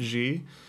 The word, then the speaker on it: Xi: There's a lot that are mocking President Xi. I think I said that right. – I think so. I think that's right.